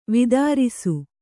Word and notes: ವಿದಾರಿಸು: vidārisu

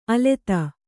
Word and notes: ಅಲತೆ: alate

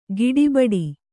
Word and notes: ಗಡಬಡ: kannada; gaḍabaḍa

